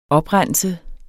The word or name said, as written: oprense